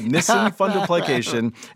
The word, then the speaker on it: Nissen fundoplication: Eventually, I had, I'm going to try to pronounce this, Nissen fundoplication. – Yeah, a Nissen fundoplication.